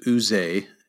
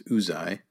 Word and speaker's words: Uzai: So this is the infamous Uzai, not to be confused with Uze. It's, I think, the preferred pronunciation is Uzai. So like the ooze coming out of your eye, that's the way I always remember it.